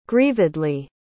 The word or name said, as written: grievedly